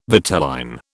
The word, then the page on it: vitelline: British English